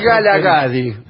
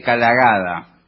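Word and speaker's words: Kgalagadi: Sí, más lindo Kgelagadi que Kgelagada.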